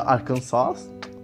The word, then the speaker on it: Arkansas: O Lions pegaram Frank Ragnow de Arkansas, não, mentira, Arkansas, tem que ter a pronúncia certinha. A Kansas é, é tem, tem origem francesa e os franceses dão a entonação na última sílaba da palavra.